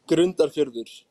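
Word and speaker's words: Grundarfjörður: Nu får du ursäkta mitt uttal här. Jag är inne och kikar på din hemkommun här. Grundarfjärberg. Var det okej? Okay? Eller säger du? Grund, eh, Grundarfjärdur.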